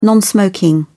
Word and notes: non-smoking: British English

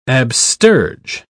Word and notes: absterge: British English

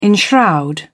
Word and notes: enshroud: British English

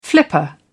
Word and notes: flipper: British English

